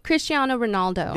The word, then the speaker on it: Cristiano Ronaldo: Cristiano Ronaldo.